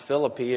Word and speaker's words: Philippi: I learned that it's pronounced Philippi if you're Greek. But I'm from Texas and I grew up, I guess, used to hearing it wrong. So I'm going to say it wrong too.